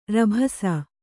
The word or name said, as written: ರಭಸ